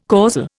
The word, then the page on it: causal: Indian